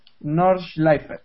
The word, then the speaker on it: Nordschleife: Ahí va mi alemán, Nordschleife.